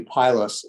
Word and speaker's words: Pylos: You can ever go to Pylos, as it's pronounced in Greek.